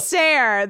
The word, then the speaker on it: Sayre: Tracy Sarah. It rhymes with hair.